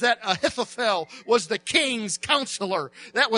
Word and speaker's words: Ahithophel: It's a man named Ahithophel, and that is the correct spelling and pronunciation of his name. Ahithophel.